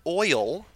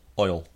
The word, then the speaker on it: oil: Is the word oil one syllable or two? – One. Oil. Oil. – Thank you. – Oil. Yeah, one.